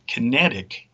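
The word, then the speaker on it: QinetiQ: There is another group that's UK-owned that's connected to the crown called Kinetic. And it's spelled with a Q, two Qs, actually. It's Q-I-N-E-T-I-Q.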